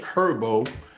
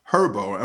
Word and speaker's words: Herbo: All right, that was G Herbo. Herbo, I'm saying that right, right? G Herbo. Herbo. Okay, because I said it, and Damo said Herb.